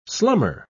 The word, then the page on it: slummer: British English